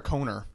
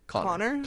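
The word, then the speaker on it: Conor: Is it Connor or Coner? – Connor. – Connor Oberst. I've heard Coner before. – Why would anyone Coner? Be named Coner? – It does have one N though.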